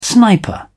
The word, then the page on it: sniper: British English